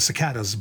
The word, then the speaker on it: cicada: Cicada. Three syllables. Unstressed, stressed, unstressed,